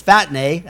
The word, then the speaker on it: phatne: Phatne, that's the way I would pronounce it. P-H-A-T-N-E, phatne.